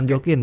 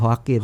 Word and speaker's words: Joaquin: Tapi gue kalau bukan Joaquin, bacanya Joaquin.